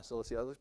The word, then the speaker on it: Cilicia: Is that how you say it? Yeah, Cilicia. It's spelled pretty close too, isn't it?